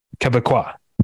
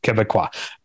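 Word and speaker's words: Québécois: No, because they don't say Quebec. It's Quebec. – Right. – Is how you say it. And so I think you would say Quebecois.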